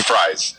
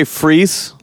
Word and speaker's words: Fries: Those like fries. It's Mr. Fries. – If I feel bad at that at all, it's because that dude is my hero, and somehow I didn't actually know how to pronounce his last name. – See, I always say freeze, like, you know, it's freeze without the German hard Z, you know. That's how I always heard it.